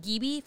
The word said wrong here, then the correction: Ghibli: festivals. G- G- um, anime Ghibli. – Festivals. Yeah, Ghibli. Yeah.